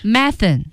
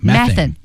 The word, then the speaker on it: methane: Methane. – Yeah. – 用的少。Methane. – Yeah. Methane. – 沼气， yeah. – 甲烷。 – British people say methane, and Americans say methane. So two pronunciations for that.